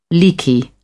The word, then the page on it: leaky: British English